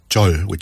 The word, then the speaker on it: 절: And 절, not to be confused with 절, which is a soft J, and it's one of those deep bows. – Oh, yeah. – You touch your head to the ground. – Yeah, right? – That's 절. – That's a different 절. – Yes. – That throws Westerners like me for a loop, whether it's a hard J or a ch.